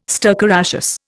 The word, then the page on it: stercoraceous: Indian